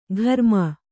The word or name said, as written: ಘರ್ಮ